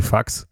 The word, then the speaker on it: Fuchs: der Name Fuchs im Englischen wie Fax quasi ausgesprochen wird und das taugt die Leute halt schon, die englischsprachigen.